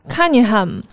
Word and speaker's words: Cunningham: 他叫 Cunningham 啊、我就叫比尔吧，我这发音不太好听了。